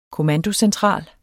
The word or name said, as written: kommandocentral